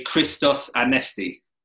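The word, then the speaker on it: Christos Anesti (Χριστός Ανέστη): Well, if you want to say it properly, you can say Christos Anesti.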